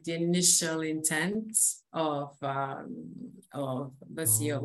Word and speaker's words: WACEO: Is that like, do I pronounce that correct? – Yeah, Waceo.